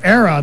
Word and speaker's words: Erra: That band is called Era. They spell it E-R-R-A.